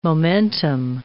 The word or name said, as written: momentum